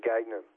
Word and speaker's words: Gagnon: Well, Gagnon is a French name, but they pronounce it Gagnon.